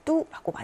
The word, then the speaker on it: 都: 이럴 때 뚜라고 많이 읽힙니다.